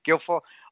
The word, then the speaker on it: Guilfoyle: Because how do you say, Guilfoyle? – Guilfoyle.